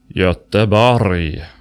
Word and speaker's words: Göteborg: It's pronounced Göteborg.